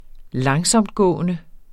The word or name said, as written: langsomtgående